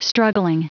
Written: struggling

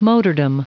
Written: motordom

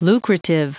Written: lucrative

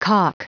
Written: calk

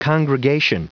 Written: congregation

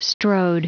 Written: strode

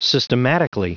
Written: systematically

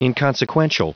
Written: inconsequential

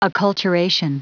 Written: acculturation

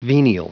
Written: venial

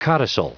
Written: codicil

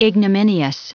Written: ignominious

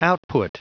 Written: output

